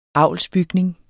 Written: avlsbygning

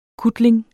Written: kutling

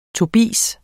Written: tobis

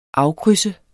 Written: AFKRYDSET

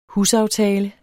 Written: husaftale

